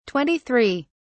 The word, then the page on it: 23: British English